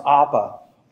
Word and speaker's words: apo: The first is apo, pronounced apa, and it means going away from something.